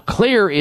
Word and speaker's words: Xlear: Now, Xlear is spelled with an X, X L E A R.